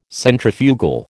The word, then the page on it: Centrifugal: American English